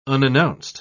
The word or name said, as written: unannounced